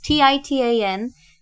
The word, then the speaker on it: Titanomachy: the Titanomachy, how do you say that word? Titanomachy? You can't move it, it's gonna squeal. It's Titanomachy.